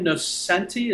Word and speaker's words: Nocenti: Ann Nocenti, is that how you pronounce her last name? – Yeah.